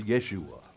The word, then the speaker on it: Yeshua: Jesus' real name was Joshua. – Und der eigentliche Name von Jesus war jo Joshua. – Joshua. as they would have pronounced Yeshua. – Yeshua wäre die eigentliche Aussprechweise.